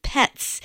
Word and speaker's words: Pez: was the eventual German Baroque composer Johann Christoph Petz. That's spelled like the Pez dispenser, P-E-Z.